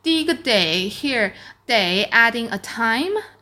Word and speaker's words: 得: when this character is pronounced day, it means it needs, it takes.